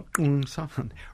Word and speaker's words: Kung San: You pronounced that correctly, the Kung San, written about by the Marshalls, by Lorna Marshall and Elizabeth Thomas Marshall, and by a lot of anthropologists. – Yeah, How did you know that's the way that's pronounced? – I've heard it pronounced in the past. – Okay, you actually got a click.